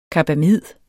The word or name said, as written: CARBAMID